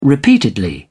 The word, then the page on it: repeatedly: British English